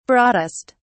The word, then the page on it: broadest: British English